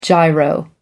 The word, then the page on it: giro: American English